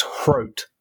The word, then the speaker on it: Hrot: So, see, I was reading a tweet from someone who was Czech as well, I think, and they were saying it's Hrot, like. Rhymes with scroat.